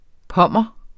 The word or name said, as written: pommer